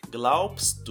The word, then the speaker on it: glaubst: Note que o B aqui ele é mudo, então ele tem um som de P. Was glaubst du?